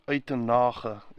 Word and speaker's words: Uitenhage: This happened in a town called Uitenhage, Uitenhage in Afrikaans, you pronounce it.